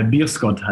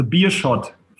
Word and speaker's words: Beerschot: Wie spricht man das richtig aus? – Ja, äh, Bierskott heißt der Verein. Bierscott, okay. – Also Bierschott. Für die HSV-Fans einfach zu merken, Bierschott.